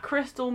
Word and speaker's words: Christel: At Christel Music, but my name is spelled Christ, E-L.